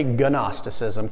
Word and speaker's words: Gnosticism: If you want to say it phonetically, Gnosticism, because there's a silent G at the front.